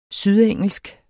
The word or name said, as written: sydengelsk